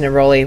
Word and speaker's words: neroli: We were both going, how do you pronounce that one again? – Yeah, I was singing. We were singing "Rolling with Neroli." I think that sounds about right. – We were. – Right? Is that it? – It is. Yeah.